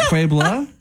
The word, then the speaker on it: Pueblo: Is it Pueblo? – It's Pueblo.